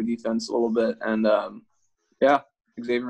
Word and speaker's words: Xavier: I think you pronounced that Xavier, right? – Yeah.